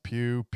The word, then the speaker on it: Pugh: Pew Puff Pa. Florence Pugh P U G H.